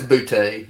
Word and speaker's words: Boutte: Is that how you pronounce his name? – Yeah, it's Boutte. Yeah.